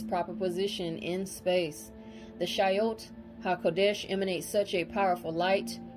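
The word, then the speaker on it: Chayot HaKodesh: Okay, first it starts with the Shayot HaKodesh. Shayot HaKodesh is spelled Shayot, first word C-H-A-Y-O-T, second word Ha, H-A, third word Kodesh, K-O-D-E-S-H.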